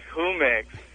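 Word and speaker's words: Jumex: My name is Humex. – Well, no, that's Humex. Way better. – Humex? – Humex, you know, like the Spanish nectarine drink.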